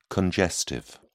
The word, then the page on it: congestive: British English